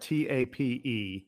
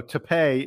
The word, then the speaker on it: Tapé: Yeah, and first of all, the most important thing his his last name is spelled T A P E, but you are correct; it is not pronounced tape like Scotch tape.